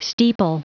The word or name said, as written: steeple